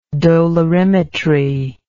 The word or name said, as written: dolorimetrically